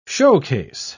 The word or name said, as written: showcase